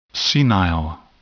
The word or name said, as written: senile